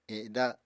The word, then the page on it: eda: Apatani